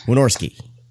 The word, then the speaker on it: Wynorski: How do you pronounce that? Warnowski. Listen to the new Splathouse episode, by the way. You'll hear Mike and Jim Wynorski going back and forth trying to say his name correctly. It's fantastic. A little plug there for Splathouse. It's great. Wynorski.